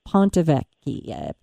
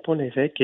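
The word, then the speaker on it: Pont-l'Évêque: Well, then how about the last cheese that you and I put on this hypothetical cheese tray of the Pontevecchi? Pontevecchi, I say it wrong. Say it beautifully. – The Pontevecchi is a washed rind cheese that comes from Normandy.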